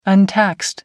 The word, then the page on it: untaxed: American English